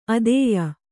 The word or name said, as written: ಆದೇಯ